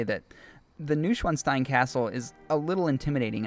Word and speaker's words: Neuschwanstein: The two drew inspiration from a few castles in Europe, but they received most of their inspiration from Neuschwanstein Castle in Germany. I might not be pronouncing that right. – No, I think you killed that.